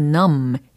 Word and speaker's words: numb: 근데 그곳이 어디냐면 where I've become so numb 이라고 했습니다. 끝부분에 발음된 numb이라는 거 철자는 n-u-m-b라는 철자죠. 예, B가 묵음 처리가 되는 거고요.